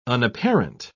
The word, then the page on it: unapparent: British English